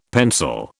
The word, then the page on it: pencil: British English